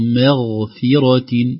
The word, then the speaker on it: مغفرة: آخر تنبيه، إن ربك لذو مغفرة، لذو مغفرة، هنا الميم حرف رقيق أو رقق بعده الغين ساكن حرف مفخم، لا نقول مغفرة مغفرة، يعني لا نرقق الميم والغين مباشرة، أو نقول مغفرة نفخم كلا الحرفين، بل نخلص ترقيق الميم من تفخيم الغين.